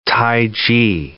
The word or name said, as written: tai chi